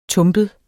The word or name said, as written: tumpet